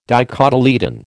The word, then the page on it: dicotyledon: American English